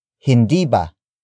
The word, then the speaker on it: hindi ba: First, it's hindi ba? Hindi ba? Hindi ba? Hindi ba is spelled as H-I-N-D-I space B-A.